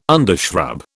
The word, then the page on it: undershrub: British English